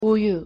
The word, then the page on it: 우유: Korean